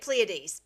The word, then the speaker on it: Pleiades: Yeah, if I pronounce it correct, Pleiades. – That's right. That's it. I say it Pleiades.